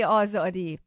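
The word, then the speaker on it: آزادی: Can I say Zen, Zendegi, and Azadi? I don't know if I said it right. – You said it correctly. Zen, Zendegi, Azadi.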